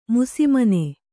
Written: ಮುಸಿಮನೆ

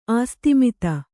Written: ಆಸ್ತಿಮಿತ

